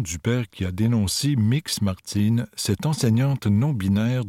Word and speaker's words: Mx: le terme MX, prononcé mix, pour désigner Martine, qui s'identifie comme ni strictement femme ni strictement homme.